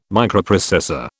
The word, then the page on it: Microprocessor: British English